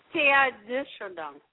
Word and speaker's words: Teilhard de Chardin: Well, without the Jesuits, I mean, I never would have heard or read Teilhard de Chardin. – That's right. – It's pronounced Teilhard de Chardin. Oh, de Chardin. Oh, okay, thank you.